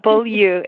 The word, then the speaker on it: Beaulieu: There's many ways to say it. So Beaulieu is how I ask people to say it, but it's fine.